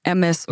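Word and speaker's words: Hermès: Habt ihr manchmal so, weil du sagst ja Hermes und wird es eigentlich Hermes oder Hermes ausgesprochen? Hermes. Okay, sorry Leute, das H spricht man nicht mit aus.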